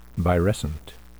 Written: virescent

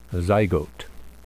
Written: zygote

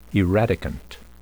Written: eradicant

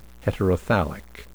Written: heterothallic

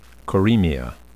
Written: coremia

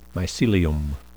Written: mycelium

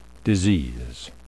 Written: disease

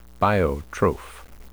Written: biotroph

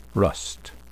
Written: rust

